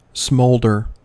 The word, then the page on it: smolder: American English